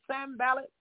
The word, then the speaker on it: Sanballat: Sanballat is Sanballat, and it's Tobai, and it's Geshem.